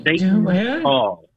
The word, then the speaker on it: Seton Hall: Actually, it's pronounced Seton Hall.